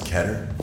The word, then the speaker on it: Keter: Did you know it's actually pronounced Keter? – Yes, oh, Simmons!